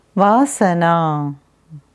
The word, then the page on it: Vasana: Sanskrit